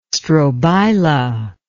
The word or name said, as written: strobila